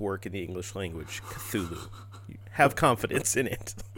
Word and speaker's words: Cthulhu: Yeah, it's like I heard a couple of different ones, but I feel like Cthulhu is the geeky agreed upon pronunciation.